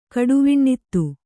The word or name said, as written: ಕಡುವಿಣ್ಣಿತ್ತು